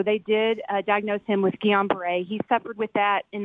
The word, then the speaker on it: Guillain-Barré: Actually, what you guys are talking about is pronounced Guillain-Barré.